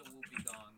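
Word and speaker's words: wobbegong: Dude, there's a shark called the Wobblegon. – Wobblegon? – Goblin shark. – He looks like a pizza. – It's pronounced the Whoopi Gong.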